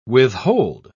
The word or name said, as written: withhold